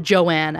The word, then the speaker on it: Joan: So on her birth certificate, her name is written as J-O-A-N, which lots of people would pronounce Joan. – Yeah. – But she preferred to pronounce it Joanne, as in like J-O-A-N-N-E. I might mess it up in the episode every once in a while, but it is Joanne.